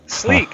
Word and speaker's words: Slique: Right, now one thing that's jumped out at me here is what's known as the Sleek Range. It's spelled Slique. – That's my shake. Sleek.